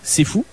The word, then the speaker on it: CFOU: Et tu tapes dans la boîte de recherche C'est Fou. Ça sera le premier résultat qui t'apparaîtra. – OK, mais puis, comment j'écris C'est Fou? – Exactement comme ça se prononce. Donc, c'est les quatre lettres, C-F-O-U.